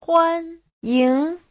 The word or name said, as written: huan1ying2